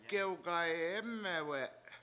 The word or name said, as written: gewgayEmewe7